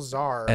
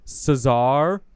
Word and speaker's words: czar: He was really more of a final czar. – And a mystic. – I'm glad you said that right, too, because a lot of people are like, Caesar or Khazar.